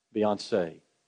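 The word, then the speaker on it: Beyoncé: Is that how you pronounce it? I see him say it right. I didn't say it right, did it? Say it right. Beyonce.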